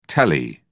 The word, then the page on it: tele: British English